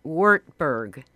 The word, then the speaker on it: Wartburg: Wartburg.